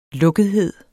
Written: lukkethed

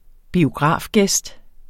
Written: biografgæst

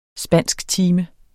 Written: spansktime

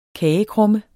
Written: kagekrumme

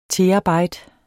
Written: terabyte

